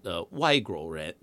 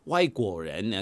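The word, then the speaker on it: Waiguoren (外国人): I'll use the I think in Mandarin they call it Waiguoren. My Mandarin is terrible. Waiguoren. I think in Cantonese say Waiguoren. And so it means like Waiguoren, which means Oi is like outside and Guo is country and Yan is people.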